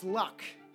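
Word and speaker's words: Lugh: It has been suggested merry tricksters of today may even be the modern incarnation of Euro-Celtic god the Lu, pronounced luck.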